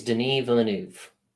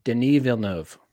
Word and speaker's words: Denis Villeneuve: We have a movie from Denis Villeneuve. Yeah. – Say his name officially. – Villeneuve. – I think we always screw it up. – I'm gonna ask. – I think it's Denis Villeneuve.